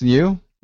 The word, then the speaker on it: Liu: And then for our guests, we have Alex Liu.